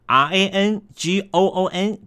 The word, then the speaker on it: Rangoon: R A N G O O N 改成了 Y A N G O N，读音上面从 Rangoon 变成了 Yangon，从这读音的大大不同。